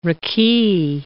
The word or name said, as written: raki